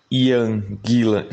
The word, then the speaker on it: Ian Gillan: engraçado porque se escreve I-A-N, por que, que a gente fala a Ian Gillan? – Você tá entendendo?